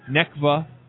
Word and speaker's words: NECVA: NECVA is the pronunciation of the acronym.